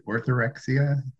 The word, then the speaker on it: orthorexia: I wanted to go back to, and I'm going to pronounce it wrong, but is it orthorexia? Orthorexia? – Yeah, you said it correctly.